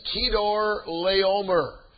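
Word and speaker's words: Chedorlaomer: So now in chapter 14, Lot is living in Sodom, and King Kedor Laomer, that's how you pronounce that name.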